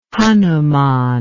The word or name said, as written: hanuman